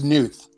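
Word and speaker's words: Knuth: It's Newth. – Nelson. – A silent K. – Newth. Gotcha. Gotcha. – Yeah, gotcha. – The K is silent. Yeah.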